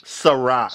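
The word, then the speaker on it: Saroc: What did you say her name was? – Ciroc. – Ciroc? – Yeah, S-A-R-O-C. I think it's S-A-R-O-C.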